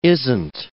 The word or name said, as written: isn't